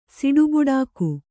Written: ಸಿಡುಬುಡಾಕು

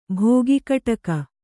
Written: ಭೋಗಿಕಟಕ

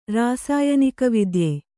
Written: ರಾಸಾಯನಿಕವಿದ್ಯೆ

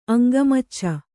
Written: ಅಂಗಮಚ್ಚ

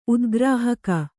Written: ಉದ್ಗ್ರಾಹಕ

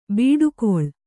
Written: ಬಿಡುಕುಳ

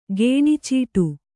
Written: ಗೇಣಿಚೀಟಿ

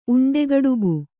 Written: ಉಂಡೆಗಡುಬು

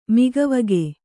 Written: ಮೊಗವಿಕ್ಕು

